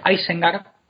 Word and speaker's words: Isengard: O, por ejemplo, Isengard, que sí sería correcto, yo creo, pronunciar Isengard, porque es, es inglés antiguo, es anglosajón.